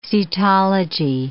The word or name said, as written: cetology